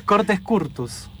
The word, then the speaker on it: Cortes Curtos: El álbum se llama Cortes Curtos, ¿cómo se dice? – Cortes Curtos. – Cortes Curtos. – Curtos. – Así lo busco. – Curtos. Cortes Curtos. – Cortes Curtos. – Sí, exacto.